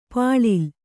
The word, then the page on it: ಪುಳಿಲ್: Kannada